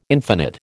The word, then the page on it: infinite: American English